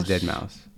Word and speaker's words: deadmau5: Dead It is deadmau5. Dead Okay. Mouse Five. – Is he house? Wait, wait, what? Which one is it? – deadmau5.